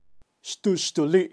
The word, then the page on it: xtuxtuli: Kaqchikel